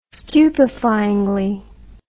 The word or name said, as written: stupefyingly